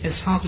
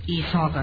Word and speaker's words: اسحاق: از اون طرف کلمه اسحاق هم تلفظ صحیح این واژه در عبری اسحاق نیست، ایساق است.